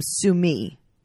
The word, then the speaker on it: Sumi: She also calls him Sumi. – Yes. Which I think is how you're supposed to say it like properly.